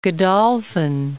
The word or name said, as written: Godolphin